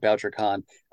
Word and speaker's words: Bouchercon: You are not alone in wondering how to pronounce it, but Yeah, and even authors at the convention sometimes ask, like, is it BoucherCon? But it's named after Anthony Boucher, a renowned crime fiction critic who reviewed mysteries for the New York Times for years and years and years.